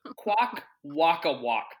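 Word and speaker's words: Kwakwaka'wakw: It's actually based on the transformation mask of, and forgive me on the mispronunciation here, of the Kwak Waka Wak tribe. And the logo... – Nailed it.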